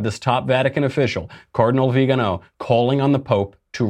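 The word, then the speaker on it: Viganò: Vigano, yeah.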